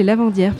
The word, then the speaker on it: lavandières: Une petite histoire de Lavantières de Concoré. – Et, les Lavantières. – Ouais, les Lavandières.